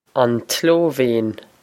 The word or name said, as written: An tSlóivéin